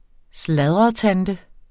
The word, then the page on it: sladretante: ˈslaðʁʌ-